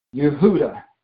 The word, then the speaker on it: Yehuda: If you look at Strong's Concordance, at the word Jew, and you look at what it is, how it was originally written and pronounced, they say it was Yehuda. Yehuda, and they also say it's Udias.